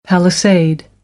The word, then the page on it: palisade: American English